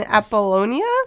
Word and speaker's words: Apollonia: Amphipolis and Apollonia? – Yep.